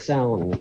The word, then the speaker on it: Samhain: Now, first up, I know it's spelt very Gaelically, but do we pronounce it Samhain? – Yeah, well, the festival is called Samhain, which is the Irish word for the month of November.